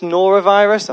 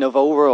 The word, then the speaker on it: norovirus: And let's be honest, the illnesses that we've had this year, there's this norovirus, I've said it right, I keep calling it Novora or something, there's this norovirus, but even just coughs.